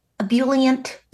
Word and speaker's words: ebullient: My mom was she was on Depakote, and I think she had some bipolar elements, but she was very ebullient. Is that the right pronunciation? – Yeah.